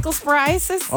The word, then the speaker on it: Cyclosporiasis: All that we've been talking about at the dinner table is this, like, Cyclosporiasis? – Is that how you pronounce it? – Yeah. – Cyclosporiasis? – All I know is, you know what?